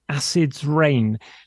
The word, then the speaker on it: Acid's Reign: Now, it's called Acid's Reign. Now, reign is spelt R-E-I-G-N. So it's as in a king's reign, not pouring with acid rain, just in case you were thinking that we've got some dangerous precipitation.